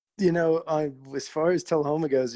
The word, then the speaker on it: Tullahoma: You are originally from Tullahoma, Tennessee. Did I say that correctly? – You did. Yeah. – All right. – You got it.